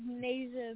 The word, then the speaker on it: Nayjah: It's Nayjah Mickens.